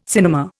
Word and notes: cinema: Indian

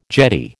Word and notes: jetty: American English

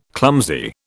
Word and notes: clumsy: British English

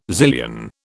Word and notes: zillion: British English

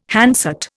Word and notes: handset: Indian

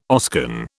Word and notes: Oscan: British English

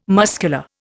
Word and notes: muscular: Indian

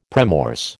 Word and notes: premorse: American English

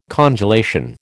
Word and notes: congelation: American English

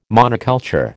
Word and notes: monoculture: American English

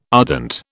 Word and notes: ardent: British English

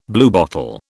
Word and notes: bluebottle: British English

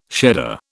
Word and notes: shedder: British English

